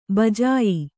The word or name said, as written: ಭೋಜ್ಯ